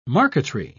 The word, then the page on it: marqueterie: British English